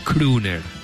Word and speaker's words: crooner: Me está escribiendo Daniel Sapsay, que dice, es verdad, que Crooner se pronuncia Crooner. Con la doble O en inglés es una U, tiene razón.